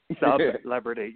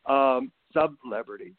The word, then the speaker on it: Sub-Lebrity: Sub-Lebrity. Yes, we got to pronounce that hyphen because I am not a celebrity. Sub-Lebrity.